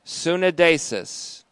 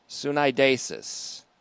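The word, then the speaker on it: sunedesis (συνείδησις): First, Lonergan, specifically regarding the conscience, which we've seen in the Greek as sunedesis, S-U-N. We've seen this before, S-U-N-E-I-D, long E-S-I-S, sunedesis, sunedesis. That's conscience.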